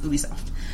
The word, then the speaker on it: Ubisoft: Ubisoft.